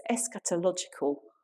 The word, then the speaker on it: eschatological: And then there's this wonderful, lovely word that I'm going to try and say correctly. It's eschatological. I hope that those of you that know theology that I've said that correctly. I'm looking at Rosemary particularly, she's nodding, so it's fine.